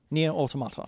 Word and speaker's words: Nier Automata: Yeah, Nier Automata.